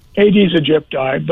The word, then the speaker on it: Aedes aegypti: Is it Aedes aegypti? The pronunciation... – Aedes aegypti, but, you know, its common name is the yellow fever mosquito.